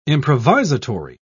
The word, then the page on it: improvisatory: British English